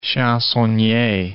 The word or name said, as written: chansonnier